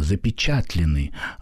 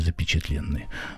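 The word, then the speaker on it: запечатлённый: И я думаю, что в этой связи правильнее ударение в названии повести ставить на другое слово – запечатленный ангел, а не запечатленный.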